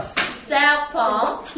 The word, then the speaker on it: serpent: You can see on here, the T on serpent is really faint. And that means that you don't pronounce it.